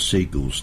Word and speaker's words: Sigils: It's not Seagulls, definitely, but it's spelled S-I-G-I-L-S, so pronounce it how it should be.